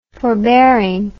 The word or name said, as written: forbearing